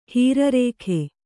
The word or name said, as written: ಹೀರರೇಖೆ